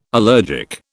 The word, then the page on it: allergic: British English